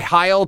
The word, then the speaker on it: Heil: I've also heard it pronounced Heel talk box. Every time I've seen one, every time I've talked to somebody about it, it's called the Heil talk box or just the talk box.